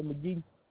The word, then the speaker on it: Magee: Tadali McGee. – How do you spell it? – E-J-A-R-A. McGee, M-A-G-E-E.